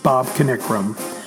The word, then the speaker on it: Bob Kanikram: I know I have trouble with Norfolk, and I know that last week I got Bob Kanikram wrong because I didn't know to pronounce the K. I do now. Bob Kanikram.